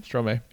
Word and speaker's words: Stromae: Stromae, yeah. – Yeah. – Is that how you say his name? – Stromae, yeah.